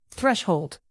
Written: threshold

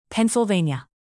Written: pennsylvania

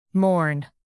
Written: mourn

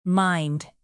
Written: mined

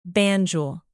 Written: Banjul

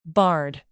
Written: bard

